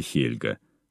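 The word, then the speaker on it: Helga: Здесь сейчас стоит памятный камень, а рядом с Выбутами проводятся исторические фестивали Хельга. Так у скандинавов произносится имя княгини.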